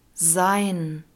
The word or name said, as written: sein